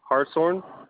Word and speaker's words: Hartshorn: Hartshorn. It's H-A-R-T-S-H-O-R-N.